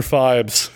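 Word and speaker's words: Phibes: The Abominable Dr. Fibes.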